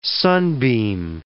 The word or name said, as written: sunbeam